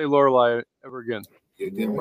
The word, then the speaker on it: Lorelai: Lorelai.